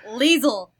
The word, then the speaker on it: Lizel: Liesel. – Liesel. – Liesel? Like weasel with an L? – Don't you dare say that. Okay. It's spelled L-I-Z-E-L.